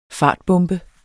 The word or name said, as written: fartbombe